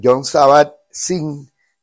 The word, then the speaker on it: Jon Kabat-Zinn: Jon Kabat-Zinn. Y se escribe, o sea, John J-O-N, Sabat, como se pronuncia, y con Z, Zinn.